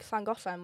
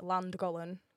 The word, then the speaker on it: Llangollen: We've got like Llangollen, which is like right next to where I live, which English people call it Llangollen because they can't pronounce the sound.